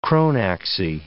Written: chronaxy

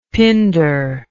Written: pinder

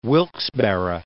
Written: Wilkes-Barre

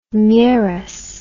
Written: mieres

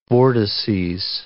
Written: vortices

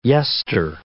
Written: yester

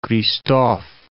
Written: Christophe

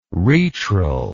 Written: retral

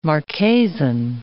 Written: Marquesan

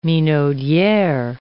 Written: minaudiere

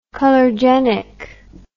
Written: colorgenic

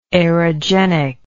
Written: aerogenically

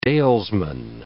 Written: dalesman

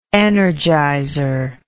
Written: energizer

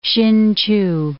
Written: Hsin-chu

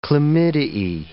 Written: chlamydiae

